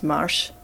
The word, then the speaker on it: marche: Mush comes from a French word, which I assume is pronounced marche.